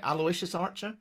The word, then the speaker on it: Aloysius Archer: Aloysius Archer? – You absolutely are, yes.